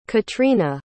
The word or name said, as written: catrina